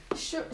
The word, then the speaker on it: Sheol: The second is the word the pronunciation. Sheol. Sheol. S-H.